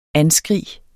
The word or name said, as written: anskrig